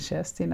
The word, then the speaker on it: Kerstin: Auf Schwedisch natürlich wird Kerstin Scherstin ausgesprochen.